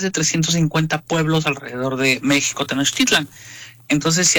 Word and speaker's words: México: En náhuatl era México con uh-huh. acento en la I. – Uh-huh. – Y la pronunciación era como shampoo. Ajá. O shower en inglés.